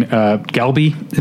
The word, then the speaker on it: galbi: So he's making galbi and a bunch of other. How do you pronounce it again? – The letter is like a consonant that's like somewhere between a G and a K.